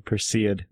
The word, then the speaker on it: Perseid: Okay, Perseid.